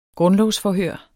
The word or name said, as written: grundlovsforhør